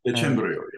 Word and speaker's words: Decembrio: Yeah, Decembrio.